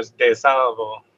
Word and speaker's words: De Salvo: But if you want to throw the Italian on there, DeSalvo.